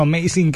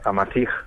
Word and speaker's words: Amasij: Bueno, lo decíamos nosotros porque nos salía como amazing, que es como la palabra en inglés, pero no es amazing. Tú nos tienes que decir exactamente cómo se dice hombre libre en bereber, que es como decimos el título de ese libro tuyo. – Pues es Amasij, moto hasta el desierto, Amasij.